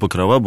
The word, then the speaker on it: Покрова: Так вот, в этом слове традиционно сложилось без всяких причин ударение на последнем звуке. Итак, праздник Покрова Божьей Матери. Это более правильно.